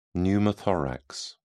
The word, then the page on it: pneumothorax: British English